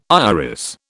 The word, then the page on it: iris: British English